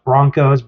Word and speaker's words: Bronchos: It might be pronounced Broncos, but there's an H.